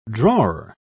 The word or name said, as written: drawer